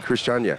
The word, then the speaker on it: Christiania: You know, we actually played Christiania. – Mm-hmm. – So did I say that correctly? – Yeah, Christiania? – Christiania.